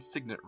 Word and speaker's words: signet: Or is it signet ring? – No, you had it right the first time. – Okay, I just want to make sure, because it is spelled sign, and then with a...